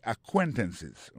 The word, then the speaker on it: acquaintances: c'est acquaintances on accent tombé sur deuxième syllabe là.